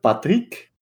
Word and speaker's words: Patrick: le film Patrick, que je prononce comme ça, pas juste parce que je suis cool, mais parce que c'est un film d'origine belge, euh, mais pas la partie qui parle français, la partie qui parle flamand.